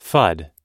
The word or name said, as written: fud